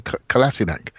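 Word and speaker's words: Kolašinac: Someone told me I say it wrong. – Kolasinac, we have that play. I think. – Well, people say I should say Kolasinac.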